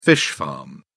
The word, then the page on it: fish farm: British English